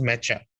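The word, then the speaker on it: Nmecha: You can have I'm going to bet on Enmecha there, but go on. – Yeah, I want to see Enmecha. I think it's actually pronounced Mecha. – Mecha? – Yeah.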